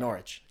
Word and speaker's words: Norwich: Norwich. Yeah, thank you. – You cannot pronounce the W in there or they will...